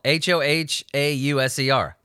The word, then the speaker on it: Hohauser: That's how it's spelled H O H A U S E R. Hohauser. It's like Ha Hogan.